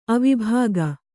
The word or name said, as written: ಅವಿಭಾಗ